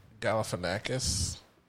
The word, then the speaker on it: Galifianakis: I watched that Zach Galifianakis. Did I say his name right? Mm-hmm.